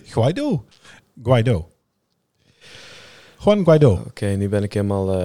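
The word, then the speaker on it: Guaidó: Guaido. – Guaido. toestand. – Guaido. – Guaido. Nee, Guido. Guido. Met de klemtoon op de O.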